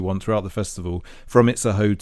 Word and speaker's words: Itzehoe: You get a couple of trains to this place called Itzehoe. I think that's how you say it.